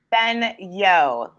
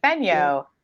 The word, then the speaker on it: Fenyo: Fenyo, like Fenyo. – Fenyo. – Yo, like the E is completely silent.